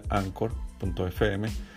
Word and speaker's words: anchor: Hay maneras a través de anchor, anchor.fm, así como suena anchor, se pronuncia anchor.fm, a que me sigan como Networking Café, donde van a encontrar más fácil.